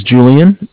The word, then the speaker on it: Julian: All right, my name is Julian. It's spelled J-U-L-I-A-N. And I'm calling from Bank of Cardiff. It's spelled C-A-R, D as in David, I-F-F as in Frank Frank.